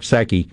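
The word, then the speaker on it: Psaki: I look up, and Fox is on the left-hand TV screen, and all I see is Jen, the P is silent, Saki, holding a press conference.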